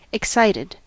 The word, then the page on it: excited: American English